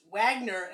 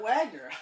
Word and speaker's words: Wagner: I didn't know you pronounced Wagner as Wagner. I always oh, said Kurt Wagner.